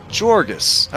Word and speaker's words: Jorgis: How would you say this in Greek? Let's see. Jorgis.